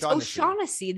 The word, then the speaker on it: O'Shaughnessy: Did you just say O'Shaughnessy? Did you just say the G? – Yeah. – O'Shaughnessy. It's O'Shaughnessy. The G is silent.